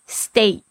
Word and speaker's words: steak: 那还有一个口味是牛排口味，牛排的口味就是 steak， S-T-E-A-K，特别注意一下它的发音，这个 E-A 发 A 的声音， steak steak。